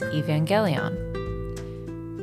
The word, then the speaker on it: Evangelion: Systems on Loop announced Evangelion with a hard G.